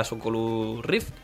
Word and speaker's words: Oculus Rift: Bueno, ya lo conoces, estuvo correcto la semana pasada, que nos presentó la Oculus Rift, ¿lo he dicho bien? – Sí.